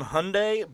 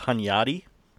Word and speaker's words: Hunyadi: It looks like John Hyundai, but I do believe it's pronounced Hunyadi. John Hunyadi.